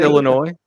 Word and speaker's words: Illinois: Did you just say Illinois, like pronounce the S? – Is that no, I said Illinois.